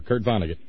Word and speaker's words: Kurt Vonnegut: Is that the way to pronounce his name? – Sure, Kurt Vonnegut.